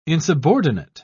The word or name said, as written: insubordinate